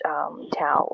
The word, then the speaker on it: Tau: It also can be pronounced Tau or spelled T-A-U, depending on a certain knowledge or wisdom or whatever you read.